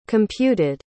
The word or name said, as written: computed